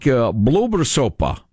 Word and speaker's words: Blåbärssoppa: I think Blåbärssoppa. – Blåbärssoppa. Oh, boy, I like the Blåbärssoppa. – Is how you pronounce it, roughly.